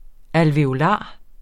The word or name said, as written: ALVEOLARER